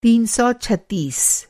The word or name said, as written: तीन सौ छत्तीस